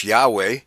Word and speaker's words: Yahweh: as it's spelled Y-A-H-W-E-H. However, and that would in English Yahweh. But in the original language, it would be Yahweh, as the W, there was no W in those. It would be pronounced like a V, okay? – V.